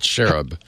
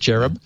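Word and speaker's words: xerub: But this hacker who goes by the handle X-E-R-U-B, X-E-Rub, I guess, or Z-Rub, don't know how you want to pronounce it. – I said cherub. – Okay, yeah, that's probably good, cherub.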